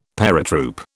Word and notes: paratroop: British English

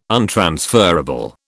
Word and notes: untransferable: British English

